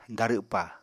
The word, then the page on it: darwpa: apatani